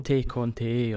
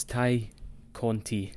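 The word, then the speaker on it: Tay Conti: That's how they pronounced it. I made sure to rewind it and listen to it again. It's not Ty Conti or Ty Conti or whatever you want to say. It's Ty Conti.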